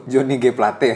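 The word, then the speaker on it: Johnny G. Plate: Johnny Geplet ya? – Johnny Geplet. Uh. Johnny G Plate anjing bacanya.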